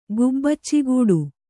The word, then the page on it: ಗುಬ್ಬಚ್ಚಿಗಿಡ: Kannada